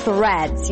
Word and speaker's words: threads: How to pronounce the word threads? You start with a voiceless TH sound, stick the tongue out, release air, then pull the tongue in for the R. Thr. Make sure the tongue doesn't touch the upper palate and round your lips.